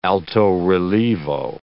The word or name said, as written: alto-rilievo